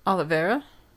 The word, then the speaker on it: Oliveira: It was run by Hector Olivia or Oliveira. I don't know. How would you say it? – How is it spelled? – Oliveira. – Oliveira? – Okay. Well, I was right then.